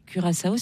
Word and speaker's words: Curaçao: Donc comment on prononce ça? – Curaçao. – Curaçao. – Curaçao. – Ah oui, Curaçao, j'aimais bien. – On va partir sur Curaçao, c'est une petite île des Caraïbes et elle participe pour la première fois à la Coupe du Monde.